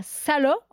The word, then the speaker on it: Salò: Alors, tu es mariée, tu as deux enfants, tu es née à Salò, dans le nord de l'Italie, je le prononce bien ou pas? – C'est très bien prononcé, oui.